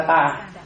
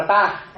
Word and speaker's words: ta: Mà cái quyền là tà. Ta chứ không phải tà nha. Việt Nam mà bỏ vô là tà kiếm á. Nhưng mà nếu mà Bali đọc là ta.